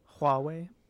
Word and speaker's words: Huawei: Huawei. – Yeah. Huawei.